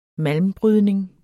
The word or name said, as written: malmbrydning